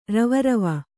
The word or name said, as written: ರವರವ